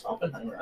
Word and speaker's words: Oppenheimer: Yeah, it's Oppenheimer.